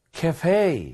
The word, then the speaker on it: cafe: 근데 이제 예를 들어서 미국식 같은 경우에는 cafe 이런 느낌이에요. 그래서 fe가 좀 늘어졌다라는 느낌으로 한번 해보세요. 가보겠습니다.